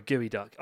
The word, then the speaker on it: geoduck: And then you discover it's Geoduck. Uh, sorry, Gooey Duck.